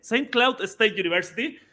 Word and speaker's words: St. Cloud State University: St. Cloud eh, State. Saint, Cloud State University. Y ahí está bien pronunciado, parece.